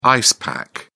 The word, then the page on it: ice pack: British English